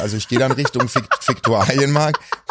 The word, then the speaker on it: Viktualienmarkt: Ja, ich gehe manchmal einfach, also manchmal laufe ich einfach Richtung Viktualienmarkt, den spricht man übrigens so aus, habe ich ja letztens erst gegoogelt.